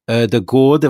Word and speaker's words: Ødegaard: Ødegaard, have I said that right that time? – There you go.